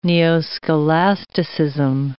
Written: neo-scholasticism

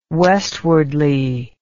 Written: westwardly

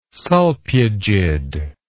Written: solpugid

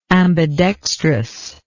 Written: ambidextrousness

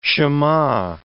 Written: Shema

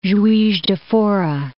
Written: Juiz de Fora

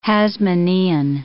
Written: Hasmonean

